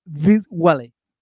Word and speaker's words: Dwale: Diwale. Se escribe D-W-A-L-E.